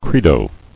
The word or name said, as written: Credo